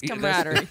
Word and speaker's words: camaraderie: It's camaraderie. – You're taking a syllable away.